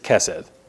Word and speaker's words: Kesed: So in Hebrew you have basically a sort of a soft C sound and a hard C sound. And our fancy word, this covenant faithful word, is Chesed. Get that nice fricative in there, right? Chesed. This isn't that. This is just Kesed.